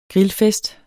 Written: grillfest

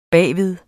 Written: bagved

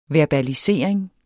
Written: verbalisering

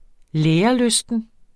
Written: lærelysten

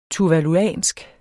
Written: tuvaluansk

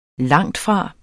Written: langtfra

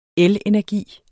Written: elenergi

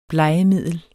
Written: blegemiddel

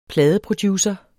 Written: pladeproducer

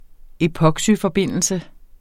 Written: epoxyforbindelse

